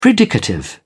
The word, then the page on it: predicative: British English